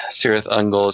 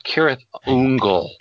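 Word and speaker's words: Cirith Ungol: Has somehow let his Sindarin become rusty, so to assist him in the name, Sirith Ungol is pronounced Kirith Ungol.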